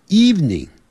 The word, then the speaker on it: evening: 只讲两个音节 evening eve evening evening evening evening evening，这样，但不要念成 evening，是 evening，那个不的音很短 evening evening good evening，那是晚上好 good evening。